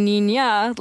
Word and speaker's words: niña: Yeah, I mean, you would just pronounce them in the Spanish, so with the I-U-A-O pronunciation, but it should be niña, like girl in Spanish.